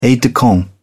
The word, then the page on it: aide-de-camp: British English